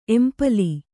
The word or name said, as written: ಎಂಪಲಿ